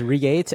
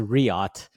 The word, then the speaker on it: Riot: I thought it was Riate and you thought it was Reet. It turns out it's Riot. I think I've checked like four different types of pronunciations and we're just going to go with Riot, okay? – Riot EXO utility, right?